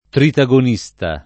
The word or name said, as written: tritagonista